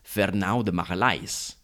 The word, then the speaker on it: Fernão de Magalhães: As regular listeners will know, usually we try to use the original pronunciation, but in the case of Magellan, Magallanes isn't even his real name. It's Fernão de Magalhães, because he wasn't actually Spanish, he was Portuguese.